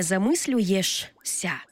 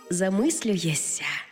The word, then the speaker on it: замислюєшся: Не замислюєшся, а замислюєшся. І не усміхаєшся, а усміхаєшся. Почули різницю, так? Замість «шся» вимовляємо «ся».